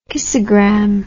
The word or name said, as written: kissagram